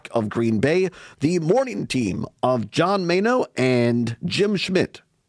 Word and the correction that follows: Mino: hosts of, I guess Mino is the correct way to say it.